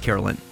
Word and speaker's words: Caroline: Even though it's pronounced Carolyn.